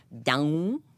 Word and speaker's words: Dão: Dão. Mm. So they, and cool pronunciation, down.